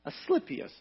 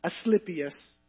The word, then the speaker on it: Asclepius: The Greek god Asclepius, I think I'm saying pronouncing that correctly. Asclepius, I think is how it's pronounced.